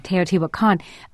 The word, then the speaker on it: Teotihuacan: If one has ever been to the pyramids outside Mexico City, I may be mispronouncing it. Is it Teotihuacan? – Teotihuacan. – Right.